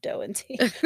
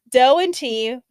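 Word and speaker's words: Do and Ti: Pronounced Doe and Tea. Literally right there. Doe and Tea, which is spelled Do and T-I,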